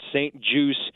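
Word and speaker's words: St-Juste: But it's spelled J-U-S-T-E, so a little French Canadian there, but it's pronounced St. Juice.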